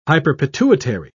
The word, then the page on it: hyperpituitary: British English